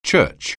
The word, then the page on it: Church: anglais